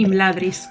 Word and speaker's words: Imladris: Y mm, yo también me estoy terminando Nieve como cenizas, que lo estamos leyendo en el Concilio de Landris, del Patreon de Amarie bueno, creo que lo he dicho bien el nombre. Imladris.